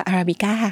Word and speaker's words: Arabica: ผลเชอรี่อยู่บนกิ่งไม้แล้วก็เป็นคำว่าอาราบิก้าเพราะฉะนั้นการอ่านชื่อแบรนด์แบรนด์นี้แบบถูกต้องเราก็จะอ่านว่าอาราบิก้า